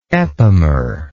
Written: epimerism

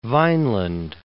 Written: Vineland

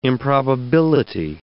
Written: improbability

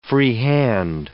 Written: free hand

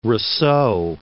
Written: Rousseau

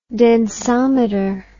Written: densometer

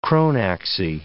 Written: chronaxie

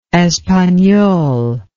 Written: espanol

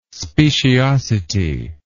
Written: speciosity

